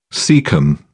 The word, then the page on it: cecum: British English